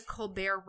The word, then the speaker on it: Colbert: mean the Colbert Report. – Excuse, excuse me. you don't pronounce the T.